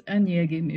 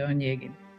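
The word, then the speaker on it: Onegin: Well, actually, it's Onyegin if you say it properly in Russian, but for the sake of English, we'll say Onyegin.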